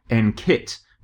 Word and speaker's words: NQIT: Yeah, well, first of all, I have to tell you off for calling it NQIT, because we've been advised to pronounce the Q with a K sound, so that it comes out as NKIT, because kit is very empowering and exciting and sounds a bit sporty, whereas quit sounds terrible.